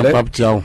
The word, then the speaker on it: Pape Thiaw: C'est une équipe sénégalaise qui n'a pas du tout existé selon moi parce que Pape Thiaw, si je sais pas si je prononce bien le nom du sélectionneur, c'est ça, Pape Thiaw.